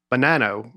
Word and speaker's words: Bonanno: That was the Bonanno.